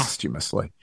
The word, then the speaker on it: posthumously: The person who sang it is going to be inducted posthumously. Is that how you pronounce that? – Yes. – Posthumously. I think that's right.